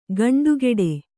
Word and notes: ಗಂಡುಗೆಡೆ: Kannada; gaṇḍugeḍe

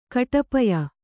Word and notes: ಕೂಟೋಪಾಯ: kūtōpāya

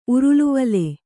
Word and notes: ಉರುಲುವಲೆ: uruluvale

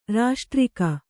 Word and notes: ರಾಷ್ಟ್ರಿಕೆ: kannada

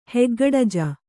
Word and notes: ಹೆಗ್ಗಡಜ: Kannada

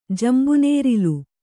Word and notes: ಜಂಬುನೇರಿಲು: jambu nērilu

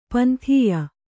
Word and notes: ಪಂಥೀಯ: panthīya

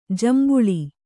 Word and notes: ಜೇಂಬುಳು: jēmbuḷu

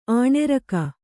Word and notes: ಅನರ್ಘ: anargha

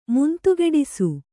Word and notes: ಮುಂತುಗೆಡಿಸು: kannada

